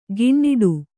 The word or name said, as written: ಗಿಣ್ಣಿಡು